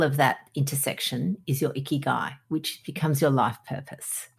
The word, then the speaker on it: ikigai: So ikigai is a Japanese phrase, and the way I remember it is it rhymes with sticky eye.